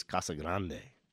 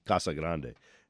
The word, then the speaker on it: Casa Grande: And we don't have words, Casa Grande, in English, so they have to be pronounced as the Spanish words they are. Their pronunciation, the correct pronunciation, is Casa Grande.